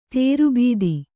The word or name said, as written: ದುರ್ಬುಧ